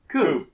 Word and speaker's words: Kubb: Can you, listen, I've now pronounced it 4 different ways. Can you just spell the name of the game for us? – K-U-B-B. – Coop. Okay. All right.